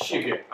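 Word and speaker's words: Skygge: But we're going to spell it anyways. It's S-K-Y-G-G-E, and none of you will be pronouncing it right, I guarantee. – It's Shiga. I stopped trying to pronounce it.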